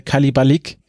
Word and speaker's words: Kalabalik: Das ist nämlich das Wort Kalabalik, so ist es richtig ausgesprochen.